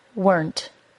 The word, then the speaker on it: weren't: Weren't is only one syllable long. I often hear this accidentally pronounced as weren't by my students. Try to say it as one syllable, weren't.